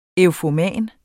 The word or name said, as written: eufoman